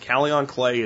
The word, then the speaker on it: kaolin: And then something you can try for cucumber beetles and something you can try for many insects is called Kalion clay. And I'll spell that for you because it doesn't spell exactly like it sounds. Kalion clay is K-A-O-L-I-N, Kalion clay.